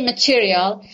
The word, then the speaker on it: material: For example, material. We say material.